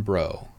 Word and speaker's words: Bro: They can find me on Instagram at action Dan Bro. with an O. B R O. Because everyone would pronounce it wrong if I spelled it the right way. – it's not Brault. Looks like Brault.